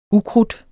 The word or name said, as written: ukrudt